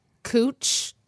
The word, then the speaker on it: Couch: Cooch. Well, it's like the street in Portland that's spelled couch, like couch, like a piece of furniture, cooch. But you say cooch.